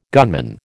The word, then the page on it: gunman: American English